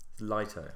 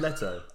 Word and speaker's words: Leto: You just said Lito, didn't you? – It's Jared Leto.